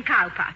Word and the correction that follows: Cowper: Cooper, darling. It is spelled Cowper, but pronounced Cooper.